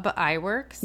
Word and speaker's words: Iwerks: Iwerks? – Yeah.